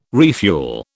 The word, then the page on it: refuel: British English